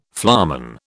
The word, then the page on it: flamen: British English